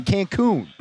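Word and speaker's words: Cancún: No, that's a U. So you have to pronounce the U correctly. Cancun. Acapulco.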